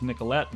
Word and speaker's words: Nicolet: 176 years ago, in 1836, Frenchman Joseph Nicolet, pronounced Nicolette nowadays, left Fort Snelling by canoe to explore and map the upper Mississippi region all the way to the river's source.